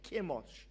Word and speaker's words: Chemosh: The gods that the Moabites served was Chemosh or Chemosh, as some people pronounce it.